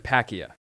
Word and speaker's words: Palkia: You butchered that. – All right. Tell me what's their... – Dialga. – Okay. – And Palkia. – Ver, that's how you pronounce those.